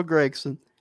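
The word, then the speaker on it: Gragson: Harrison Burton and Noah Gregson and that is how you say his name, by the way.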